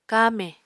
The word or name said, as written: kamè